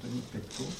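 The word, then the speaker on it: Denis Pétau: Des travaux d'érudition vont être menés par Denis Pétao, qui est un français en fait, on pourrait prononcer en français, on va dire Denis Pétao,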